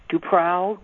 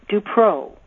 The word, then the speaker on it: DuPrau: My last name is spelled capital D-U, capital P-R-A-U. It's originally a French name. People pronounce it wrong all the time. Duprau, Dupreau, Dupreau, even Dupran. But it's pronounced Dupreau with a long O. Think of the word pro, as in tennis pro or golf pro.